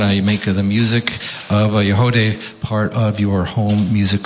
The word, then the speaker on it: Yagody: If you want to know more about Yahodeh, again, it's spelled Y-A-G-O-D-Y, all you have to do is go to the Woodsongs website, click on the archive page.